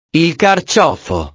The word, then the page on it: il carciofo: italiano